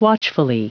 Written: watchfully

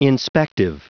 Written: inspective

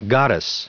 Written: goddess

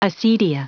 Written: acedia

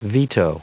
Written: veto

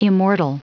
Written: immortal

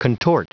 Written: contort

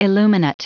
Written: illuminate

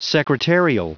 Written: secretarial

